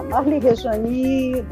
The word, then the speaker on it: Marli Rejani: Ficou Marli Rejani porque e enfim, né, com o i pronunciado, porque a nossa língua sempre no final tem aquele, aquela pontuação, que tem a ver com os indígenas também.